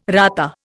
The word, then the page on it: rata: Indian